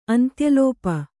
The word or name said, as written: ಅಂತ್ಯಲೋಪ